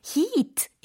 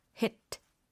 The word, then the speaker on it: hit: 이게 heat 이게 아니라 hit 이렇게 발음을 해주셔야 됩니다.